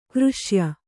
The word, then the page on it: ಕೃಷ್ಯ: křṣya